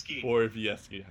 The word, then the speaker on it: Borowiecki: Oh, I guess Ryan Callahan, Clark MacArthur, and Mark Barjowiecki. I never know. Barokop. Borowiecki, of course, I messed that up. I butchered that pronunciation.